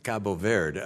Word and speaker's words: Cabo Verde: so I think of it being called Cape Verde. It's Cabo Verde, which is a different pronunciation, but I appreciate your willingness to be of service there.